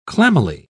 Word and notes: clammily: British English

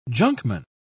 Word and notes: junkman: British English

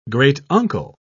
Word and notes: great-uncle: British English

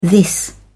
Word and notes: this: British English

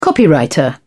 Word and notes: copywriter: British English

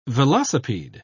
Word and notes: velocipede: British English

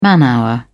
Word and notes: man hour: British English

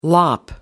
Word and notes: lop: British English

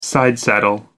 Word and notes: sidesaddle: American English

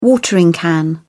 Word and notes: watering can: British English

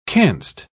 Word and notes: canst: British English

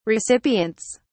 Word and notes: recipients: British English

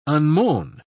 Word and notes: unmown: British English

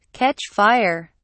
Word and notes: catch fire: British English

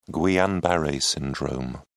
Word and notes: guillain-barre syndrome: British English

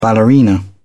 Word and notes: ballerina: British English

